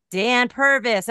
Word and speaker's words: Purvis: I am here today with my new friend, Dan Purvis.